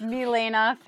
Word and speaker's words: Milaena: I don't want to rain on your parade, but it's Milaena Ferrarese.